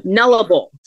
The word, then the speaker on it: Nullarbor: So it's pronounced Nullarbor.